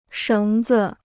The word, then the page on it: 绳子: shéng zi